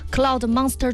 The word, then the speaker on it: Cloud Monster: 而且今天又帮大家重新谈到了新品，就是昂跑他们家的这个 Cloud Monster 2 啊，不知道这个发音对不对啊？ – 是的，非常之正确。